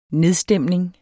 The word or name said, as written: nedstemning